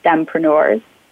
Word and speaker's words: STEMpreneur: We're now STEMpreneurs. – I love it. Thank you very much. And STEMpreneur rhymes with FemPreneur.